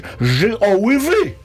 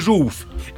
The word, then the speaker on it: żółw: To by się powinno czytać ży o ły wy. Żółw. – Ale czyta się żółw.